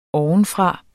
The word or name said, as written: ovenfra